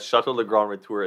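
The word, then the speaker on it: Château Le Grand Retour: But Chateau Le Grand Retour, Guillaume, you can correct me on the pronunciation, but to me, I read that as Chateau Le Grand Retour, and that's Chateau the Big Comeback. – That's right. Yeah, it's pretty much like a reference to Back to the Future, right?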